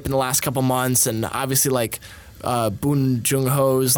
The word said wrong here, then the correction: Bong Joon-ho: like... – Bong Joon-ho. – Yeah, definitely said that wrong.